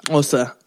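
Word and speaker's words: Xhosa: I think it's pronounced Hosa or Kosa. – Hosa. Yeah, it's with a click. Hosa.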